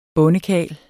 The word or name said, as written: bondekarl